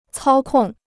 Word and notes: 操控: cāo kòng

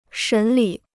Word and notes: 审理: shěn lǐ

